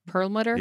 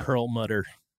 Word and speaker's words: Perlmutter: How do you pronounce his name? Perlmutter? – Yeah. – Perlmutter.